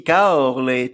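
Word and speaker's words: Caorle: Euh, moi, je vais suivre, euh, bah, je vais suivre aussi Kaorle, tu vois, c'est comme ça que ça se prononce.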